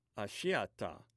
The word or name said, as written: ashiata